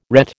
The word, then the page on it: ret: American English